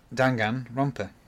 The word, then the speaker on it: Danganronpa: Yeah, no, but some people are like Danganronpa, and they're like, oh, M-M-M, and it's like, no, there's no M.